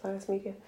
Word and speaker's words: Matthias Media: By Interactive Bible Studies, Matthias Media. That's how they pronounce it, Matthias Media.